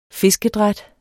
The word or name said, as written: fiskedræt